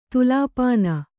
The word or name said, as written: ತುಲಾಪಾನ